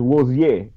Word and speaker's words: Rozier: First of all, I speak French and it's Rozier, so it's still only two syllables.